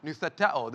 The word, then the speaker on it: noutheteo: So, noutheto... Noutheteo, there we go, I'm sorry, I knew I didn't mispronounce it.